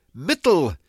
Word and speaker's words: Mittel: So you can see the connection here between Media, Indo-European, Old English, that is Germanic, middle, and of course the German is pronounced Mittel, M-I-T-T-E-L.